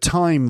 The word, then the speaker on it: thyme: So, but thyme, the herb, is spelt T-H-Y-M-E. Which sounds exactly like time, you know, the thing that just keeps happening.